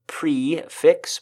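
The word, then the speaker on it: prix fixe: It goes by the fun name of Prefix, but it's spelled Prixfixe.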